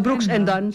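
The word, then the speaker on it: Brooks & Dunn: En toen kwam de sounds van Florida Georgia Line, Jason Aldean, Luke Bryan, George Strait, Alan Jackson en Brooks Dunn bij hem voorbij. Toen was hij om. – Brooks Dunn of Brooks Dunn? – Brooks en Dunn, sorry. Klemtoon. Okay.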